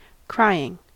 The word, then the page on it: crying: American English